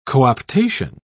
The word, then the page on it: co-optation: British English